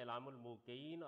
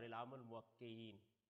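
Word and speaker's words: إعلام الموقعين: یہ کتاب جو ہے اس کے دو تلفظ ٹھیک ہیں, علام الموقعین اور علام الموقعین. اس کی وجہ یہ ہے کہ ایقاع اور توقیع ایک ہی چیز ہے, مطلب ہے ملہمین یعنی فرشتوں کا اعلان.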